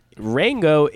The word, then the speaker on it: Rango: I it is really interesting, and just briefly, we're not doing a Rango thing, but Rango is it rhymes with Django, but it also it's the rhymes it's with tango.